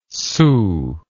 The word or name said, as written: soo